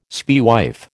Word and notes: spaewife: American English

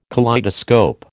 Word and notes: kaleidoscope: American English

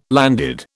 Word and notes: landed: British English

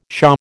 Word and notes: shaw: American English

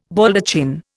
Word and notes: Baldachin: Indian

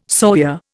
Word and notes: sawyer: Indian

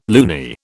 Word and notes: loony: British English

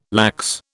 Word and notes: lax: British English

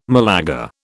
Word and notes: Malaga: British English

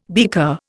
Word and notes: beaker: Indian

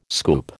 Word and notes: scoop: American English

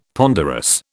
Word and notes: ponderous: British English

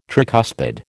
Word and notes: tricuspid: American English

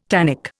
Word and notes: tannic: Indian

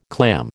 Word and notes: clam: American English

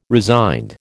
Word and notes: resigned: American English

